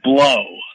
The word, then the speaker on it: Blough: The main source, his name is, it's Joe, and the last name is spelled B-L-O-U-G-H, which is, he pronounced it blow.